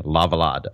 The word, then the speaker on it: Lavallade: They're also being honored alongside Carmen de Lavallade, who is a great dancer choreographer, but it took me forever to hear online how to pronounce her name, Lavallade.